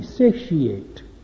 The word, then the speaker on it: satiate: It's normally three, satiate. 1, 2, 3.